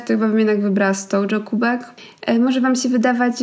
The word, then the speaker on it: Stojo: Myślę, że teraz wielu z Was już szokuje, że kubki Stojo wymawia się Stojo, bo to nie jest polska firma.